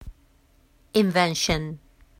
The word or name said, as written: invention